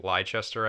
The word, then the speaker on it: Leicester: Leicester, I think is the way you say it.